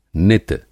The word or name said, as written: नित